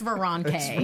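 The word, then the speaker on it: Veranke: She's, I never know how to pronounce it. Veronke. – Sure. – We'll say Veronke. – I confirm it's Veronke.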